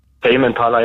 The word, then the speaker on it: Tame Impala: Tame Impala, jetzt weiß ich, wie er richtig ausgesprochen wird.